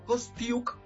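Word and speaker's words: Kostyuk: Va contra la ucraniana, contra Kostui y Kostiuk, para ser exacto en su pronunciación, y voy con Coco Gauff gana y con Fritz gana en dos sets hoy.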